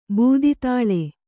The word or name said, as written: ಬೂದಿತಾಳೆ